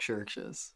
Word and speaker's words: Chvrches: They're called Churches. – Oh, but yeah. – They spell it.